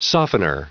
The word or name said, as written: softener